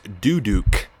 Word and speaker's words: duduk: It's pronounced Duduk. Interesting. It's spelled D U D U K. Duduk.